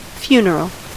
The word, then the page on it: obsèques: France (Paris); [de.z‿ɔp.sɛk]